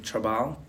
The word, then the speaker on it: Trabal: First name Wilson, W-I-L-S-O-N. Last name Trabal, T-R-A-B-A-L hyphen Figueroa, F-I-G-U-E-R-O-A.